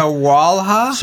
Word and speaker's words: Sawalha: How do we pronounce his name? Sawalha.